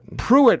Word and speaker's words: Pruitt: And, you know, Pruitt rhymes with do it.